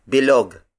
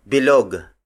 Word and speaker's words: bilog: The first shape is bilog. Bilog. Bilog. Bilog is spelled as B I-L-O-G.